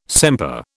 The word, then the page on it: sempre: British English